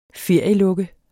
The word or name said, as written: ferielukke